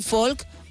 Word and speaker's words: folk: Empezamos con un folk o folk, como dirían ellos. Esto lo debatimos en su momento. En el No Sonoras me mirasteis con cara rara. Bueno, pues no pronuncian la L.